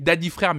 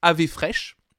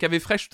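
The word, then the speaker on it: Daði Freyr: C'est vrai que c'est Sophie explique donc euh, c'est euh, donc ça s'écrit Daddy frère mais apparemment ça se prononce Ave fraîche. Effectivement, on a bien compris qu'avec Ave Fresh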